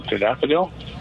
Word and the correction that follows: Tadalafil: Tadalafil. You left the L out. – Go ahead. Oh, Tadalafil.